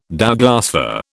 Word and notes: Douglas fir: British English

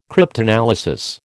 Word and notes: cryptanalysis: American English

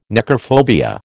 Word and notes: necrophobia: American English

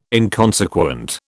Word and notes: inconsequent: British English

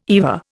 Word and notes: EVA: Indian

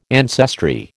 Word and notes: ancestry: American English

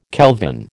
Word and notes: kelvin: American English

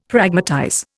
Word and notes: Pragmatize: Indian

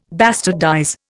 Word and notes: bastardize: Indian

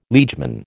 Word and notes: liegeman: American English

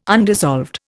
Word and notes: undissolved: Indian

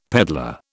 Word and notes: peddler: British English

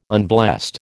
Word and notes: unblessed: American English

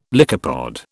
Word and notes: lycopod: British English

